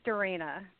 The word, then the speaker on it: Dorena: My name is Dorena. – Dorena? How do I spell that? – D-O-R-E-N-A.